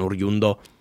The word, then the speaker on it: oriundo: Like Alfredo Di Stefano, who used to play for the Spanish national team, I think was considered an oriundo. So was How do you say it? – Oriundo. – Orgundio. – Oriundo. Oriundo.